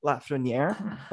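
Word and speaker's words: Lafrenière: like Lafreniere, okay? Wow, I said his name right.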